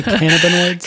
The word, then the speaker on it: cannabinoids: I was hoping you could talk about CBD, the benefits, and what's up with cannabinoids. Did I pronounce that correctly? Cannabinoids? – Cannabinoids. – Put the emphasis on the wrong syllable.